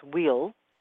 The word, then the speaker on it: weal: The word spelled W-E-A-L, which is the ancient archaic English pronounced wheel, like a W, like a long E-L, spelled W-E-A-L, is a formal noun that means that which is best for someone or something.